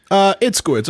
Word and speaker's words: Itzkowitz: Itzkowitz.